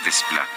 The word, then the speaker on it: Desplat: Alexandre Desplat, eh, o Desplat, creo que así se pronuncia, sí, sí, la S intermedia no se pronuncia.